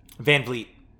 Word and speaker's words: Vliet: Van Vliet. – Van Vliet. It's the Favre thing. – Yo. – I know it's spelled Vliet, but I always see it as V-I-L in my head.